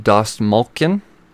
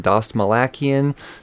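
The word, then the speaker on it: Dastmalchian: Number 2, David Dostmalchian. Look at that, I said it right. I've been saying Dostmalakian, Dostmalakian. I've been butchering his name.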